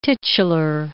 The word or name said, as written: titular